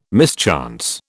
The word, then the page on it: mischance: British English